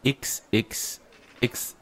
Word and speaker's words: XXXL Lutz: In der letzten Sendung habe ich einfach bemerkt, dass ich es, glaube ich, nie richtig ausgesprochen habe. – Es sind 3 Xe. XXX Lutz.